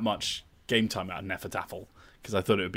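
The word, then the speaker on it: Hnefatafl: But it's Hnefataffel. So when I got the box and I tried to read it, it was a struggle. But apparently it's pronounced Nefertaffel.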